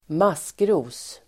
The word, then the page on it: maskros: [²m'as:kro:s]